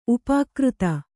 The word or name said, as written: ಉಪಾಕೃತ